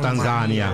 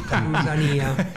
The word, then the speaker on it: Tanzania: Tanzania. – Perché Leopoldo va... – Accento aperto sulla I, Tanzania.